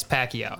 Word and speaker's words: Pacquiao: How do you say that he's last Pacquiao? – Name? Yes, Pacquiao.